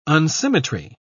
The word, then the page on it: unsymmetry: British English